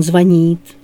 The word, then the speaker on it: звонит: А правильно: он звонит, они звонят, ты позвонишь, мы созвонимся.